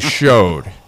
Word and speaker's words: shewed: And that one goes, and just so you know, in our ritual and a lot of places, it's spelled S-H-E-W-E-D. If you ever want to make a past master freak out, pronounce it shewed, because it's actually showed. And it's just it's an old spelling of the word, but it is still pronounced showed.